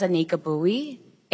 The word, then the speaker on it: Anika Bowie: My name is Anika Bowie, A-N-I-K-A-B-O-W-I-E.